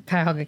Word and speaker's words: Cuyahoga: Oh, a 2005 filing by Figueroa in Cuyahoga County.